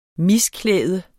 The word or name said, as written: misklæde